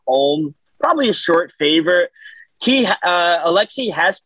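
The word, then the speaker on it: Alexy: Alexi is how you pronounce it for the Rangers.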